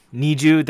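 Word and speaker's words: NiziU: Niju, that's the Japanese pronunciation and the Korean pronunciation.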